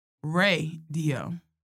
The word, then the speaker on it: RaeDio: Issa Rae has created her own record label, and it's called, wait for it, Rae Dio. Get it? Issa Rae. And it's spelled R-A-E-D-I-O.